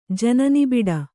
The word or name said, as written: ಜನನಿಬಿಡ